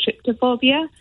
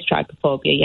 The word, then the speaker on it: trypophobia: it comes under the umbrella term of trypophobia, or is it tryptophobia? – Right. – One of the two, I can't ever pronounce it correctly. I think it is trypophobia, yeah.